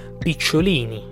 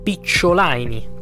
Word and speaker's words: Picciolini: Picciolini, Picciolaini, come dicono gli americani. Nessuno riesce mai a pronunciarlo come si deve questo cognome,